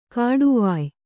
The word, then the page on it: ಕೊಡುವಾಯಿ: koḍuvāyi; dial.